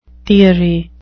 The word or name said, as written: theory